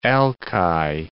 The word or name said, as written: al-ki